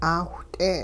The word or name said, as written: 'aawht'e